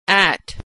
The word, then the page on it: at: American English